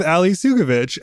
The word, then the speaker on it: Ali Soukovich: So we're talking about Steel Magnolias, and we're talking about it with Ali Soukovich.